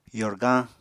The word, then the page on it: yorgañ: apatani